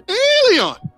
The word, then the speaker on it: allelon: Eleon is spelled A-L-L-E-L-O-N. Now, I know some of my coming to America people say, Eleon, Eleon. No, not that word, but it's close. It's Eleon, A-L-L-E-L-O-N, which means one another, each other, mutually, or reciprocal.